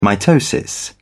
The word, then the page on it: mitosis: British English